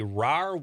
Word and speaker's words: Rahr: Greg is the, I don't have my glass on, executive director of the RAR West. Am I saying that right? – You are saying RAR, it right. – RAR R-A-H-R West Art Museum in Manitowoc.